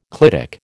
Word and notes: clitic: American English

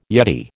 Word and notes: yeti: American English

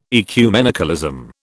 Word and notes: ecumenicalism: British English

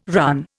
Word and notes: run: Indian

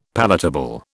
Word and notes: palatable: British English

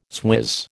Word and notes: swizz: American English

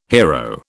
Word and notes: hiero: British English

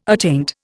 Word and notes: attaint: Indian

